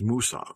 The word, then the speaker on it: Mwsog: It is spelled M-W-S-O-G, which translates to English in moss. But if you say it in Welsh, it is Moosog.